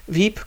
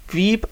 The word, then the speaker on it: Veep: Veep, kurz zur Erklärung, sind die Buchstaben V und P. Veep, oder sozusagen spitznamisch Veep ausgesprochen.